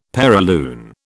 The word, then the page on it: perilune: British English